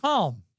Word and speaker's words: calm: The calm before the store. Does he want me to say column or calm? – Calm. Calm. You say it exactly right. Calm. – Well, I don't pronounce L in calm.